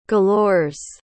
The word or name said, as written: galores